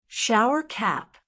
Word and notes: shower cap: American English

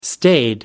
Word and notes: staid: American English

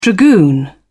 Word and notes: dragoon: British English

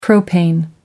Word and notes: propane: American English

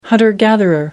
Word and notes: hunter-gatherer: American English

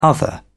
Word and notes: other: British English

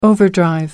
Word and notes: overdrive: American English

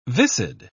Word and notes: viscid: British English

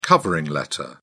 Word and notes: covering letter: British English

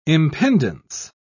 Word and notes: impendence: British English

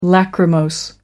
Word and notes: lachrymose: American English

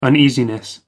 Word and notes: uneasiness: American English

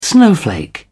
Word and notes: snowflake: British English

